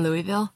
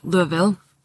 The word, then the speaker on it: Louisville: Uh, I will say my allergies are so bad here in Louisville. Louisville. I keep saying it wrong. Louisville.